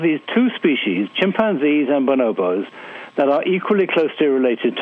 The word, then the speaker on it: bonobos: but am I pronouncing this right, the bonobos? Why and how is it that we're much more like the bonobos? – Yeah, that's right.